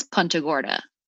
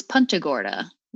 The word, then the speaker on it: Punta Gorda: It is Punta Gorda. – I've been saying Punta. – It is Punta Gorda. Which is like punting a, soccer, football, etc.